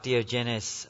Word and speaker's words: Diogenes: it looks like Diogenes, a famous philosopher. Someone might be able to help me here if you know. No? I'm right. Okay, there you go. Alexander found Diogenes standing alone in a field, looking intently at a large pile of bones.